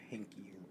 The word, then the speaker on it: Heinicke: I like Taylor Heinicke, however you pronounce it. Yeah. – Like the beer.